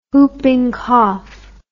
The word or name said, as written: hooping cough